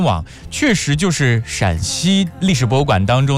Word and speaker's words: 陕西: 他说因为陕西是一声，山西是一声，陕西是三声，用双写的 A 代表声调，所以是多了一个 A，叫做山安西。